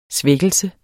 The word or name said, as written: svækkelse